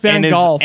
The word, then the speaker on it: Van Gogh: No. It's pronounced Van Gogh.